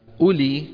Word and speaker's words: أولي: أولي كما ترون أيها الإخوة كانت عادة العرب أن يكتبوا فيها واوا، أولي، ولكن هذه الواو تكتب ولا تلفظ، يعني لا نقول أولي تبهنا، بل نقول أولي.